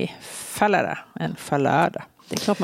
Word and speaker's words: Fallada: han ville absolut att det skulle uttalas som Falada, för det läste om en trumpetstöt medan Falada, beroningen på andra stavningen, det lät som någon som snubblade ut för en trappa och det ville han inte.